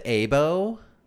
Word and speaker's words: Aibo: So the Aibo, it came It's pronounced Aibo.